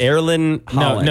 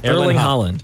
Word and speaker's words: Haaland: Erling Haaland. – No, you're making it harder than it is. Erling Haaland.